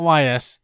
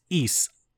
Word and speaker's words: Ys: It's not YS. That's just the two letters in it. It's Ease. That's how you say it. Ease. – Ease. Ease? – Ease. Ease. Like you're saying East, but without the T.